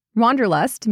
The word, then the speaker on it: Wanderlust: Wanderlust means a strong desire to travel. In German, it would be pronounced Wanderlust, but in English, wanderlust.